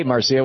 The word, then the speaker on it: Marcia: Marcia, am I saying that right? In Angier, North Carolina? – Marcia. – Yeah. I'm sorry, there's no R on the screen. Hey, Marcia, what's up? – Oh, it's okay.